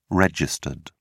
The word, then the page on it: regd: British English